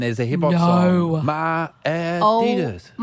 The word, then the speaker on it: Adidas: and apparently they're pronounced Adidas.